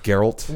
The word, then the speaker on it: Geralt: It's pronounced Geralt.